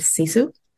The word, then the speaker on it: sisu: I ended up stumbling upon this concept from my own native culture, which you pronounced so beautifully and perfectly, which is Sisu, spelled S-I-S-U.